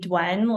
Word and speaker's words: Duyen: So I pronounce my name Duyen, like D-W-E-N.